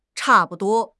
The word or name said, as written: cha4 bu5 duo1